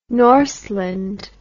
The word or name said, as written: norseland